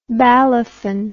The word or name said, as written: balafon